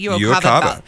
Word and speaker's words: UOCAVA: So UOCAVA. – Yes, Did I say that right? UOCAVA.